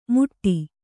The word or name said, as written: ಮುಟ್ಟಿ